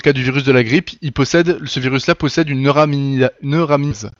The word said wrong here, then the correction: neuraminidase: C'est le N de H5N1, par exemple. Cette neuraminidase, elle lui sert à faire le... Attends, c'est dur à prononcer en plus. Neuraminidase, d'accord.